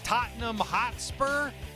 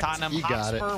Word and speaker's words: Tottenham Hotspur: At Tottenham Hotspur. I don't know how to say it. – You got it. – That's correct. – Tottenham Hotspur, whatever that means.